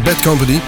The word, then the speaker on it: Bad Company: Deden we samen met Bad Company, oftewel Bad Company. Zo moet je het uitspreken.